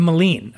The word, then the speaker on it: Malene: Maline? – Nope. The Maline. Okay. – It's from Chamisol. – From Chamisol, which we had on the last one. Okay. And we hope we're saying this right. Maline, M-A-L-E-N-E.